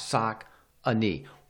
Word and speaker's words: Saucony: At the same time, there is an official way to say Saucony. They used to put the pronunciation key on the box. Saucony.